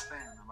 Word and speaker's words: salmon: It's, it's like the L and salmon. Like you don't pronounce the L and salmon.